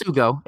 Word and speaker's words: Sugo: It's Sugo. – Okay, Sugo. Yeah. Okay. That almost looked too easy. That looked like a trap. There. So Super Formula returns this weekend at Sugo.